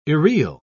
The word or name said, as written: irreal